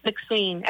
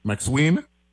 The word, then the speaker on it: McSween: McSween. M-C-S-W-E-E-N. – M-C-S-W-E-E. McSween? – Yeah, McSween.